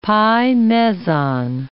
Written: pi-meson

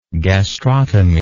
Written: gastrotomic

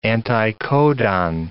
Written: anticodon

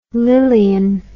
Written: lilian

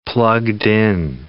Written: plugged-in

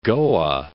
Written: Goa

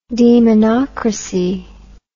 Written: demonocracy

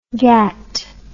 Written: GATT

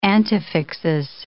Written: antefixes

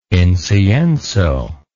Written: incienso

